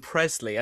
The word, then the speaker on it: Presley: clarify the pronunciation of the Democrat candidate in Mississippi because he's actually Brandon Presley, and he's the second cousin of Elvis Presley.